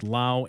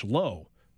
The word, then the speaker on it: Lowe: Isak Paredes, Yandy Diaz, Brandon Lau, and Josh Lowe, spelled the same but different pronunciations for Lau and Lowe.